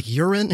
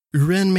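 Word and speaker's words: U'Ren: it looks like Uren, it's U, apostrophe R-E-N, Uren maybe,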